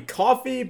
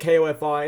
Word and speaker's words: Ko-fi: Uh, well, it might be coffee, but it's spelled K O F I, and that's weird, so I say it Kofi.